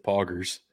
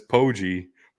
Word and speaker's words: Poggi: His name is Biff Poggers. Because that's not how you pronounce it. It's Poggi.